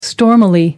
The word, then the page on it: stormily: American English